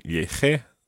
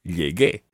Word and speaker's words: llegué: Nobody would ever say, I arrived by saying lleje. sounds really strange. You have to say llegué. So to make the G into a hard G sound, even though it's got to be followed by the E, you add in a U. It's a silent U, so it becomes llegué.